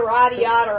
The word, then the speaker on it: Radiata: That's how Freddy said it, and I was like, dude, that actually freaking makes sense. – Radiata stories? – Radiata. – So that's the official pronunciation. Okay, radiata stories. We have it. You heard it here.